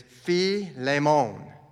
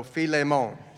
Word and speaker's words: Philemon: But if you were going to go by the dialect that I was taught, it would be called, look at there, it's Philemon, almost like it's French. And the accent is on the lay. So Philemon.